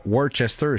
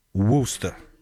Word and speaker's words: Worcester: C'est pas Worcester, c'est Worcester. Ils prononcent ça de même.